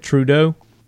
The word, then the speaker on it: Trudeau: Trudeau? – Yep.